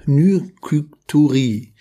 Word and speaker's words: Nykturie: Ja, es geht um Nykturie, also das wird unterschiedlich ausgesprochen, aber N-Y-K-T-U-R-I. Nykturi.